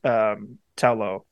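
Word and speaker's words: Ta Lo: So the voice of the character, the teacher at Tao Lo. – Mm-hmm. – Am I saying that right? – Mm-hmm. Yeah, so. It is Tao Lo. Tao Lo.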